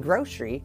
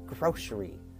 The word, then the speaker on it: grocery: And grocery, G-R-O-C-E-R-Y, is pronounced like there's a sh in there. So it's grocery. Some people pronounce it grocery, but when you're speaking quickly, it's grocery. Here we go. We're going to go in the doors and up the aisles. A-I-S-L-E.